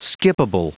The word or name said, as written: skippable